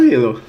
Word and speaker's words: Hillel: depois alguém souber uma pronúncia melhor me fala, mas eu acho que é Hillel, né? O primeiro nome do, do guitarrista. Eu falo Hillel.